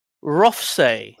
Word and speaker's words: Rothesay: It's pronounced Rothsay.